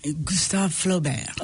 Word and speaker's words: Gustave Flaubert: Gustave Flaubert.